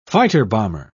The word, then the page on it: fighter-bomber: British English